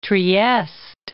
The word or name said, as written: Trieste